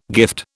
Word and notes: gift: British English

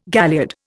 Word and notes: galliard: Indian

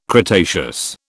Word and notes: cretaceous: British English

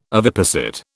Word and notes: oviposit: British English

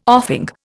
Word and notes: offing: Indian

